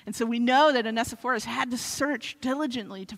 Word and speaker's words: Onesiphorus: Google doesn't get that one right. It's Onesiphorus.